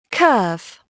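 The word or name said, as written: curve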